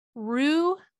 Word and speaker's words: loup: Don't come at me, but it is because Roux, which is actually spelled L-O-U-P, means wolf, and Garou means man who turns into wolf.